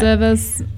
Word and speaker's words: Zervas: Yeah, Zervas.